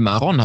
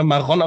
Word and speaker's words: Maron: Hast du jetzt gerade Maron gesagt? – Nee, Maron habe ich gesagt, wie es auch richtig ist. – Ja, aber jetzt hast du gerade Maron auf der zweiten Silbe, wie Ariel Sharon.